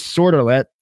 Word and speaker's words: Sordelet: His name is Sordelet, S-O-R-D-E-L-E-T-T. He says, this is how you pronounce it. The cat was sort of let out of the bag.